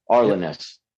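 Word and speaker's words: Arlen Ness: Is it Arness or Airness? – Arlen. – Like, you know, the triangle one? Arlenness. Yep.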